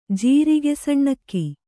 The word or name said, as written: ಜೀರಿಗೆಸಣ್ಣಕ್ಕಿ